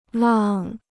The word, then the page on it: 浪: làng